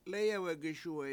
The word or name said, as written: lEyewegeSUwe7